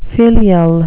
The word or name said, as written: filial